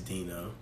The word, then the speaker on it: D8NO: My name is spelled D-8-N-O. You know what I'm saying? Despite it is Dino.